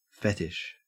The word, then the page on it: fetish: Australian English